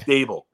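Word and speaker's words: Daboll: But you know what's the guy named Dabo? Am I saying his name right? – Ryan. The Giants coach? – Yeah. – Dable. It's like Dable. Yeah. It's like Table with the D.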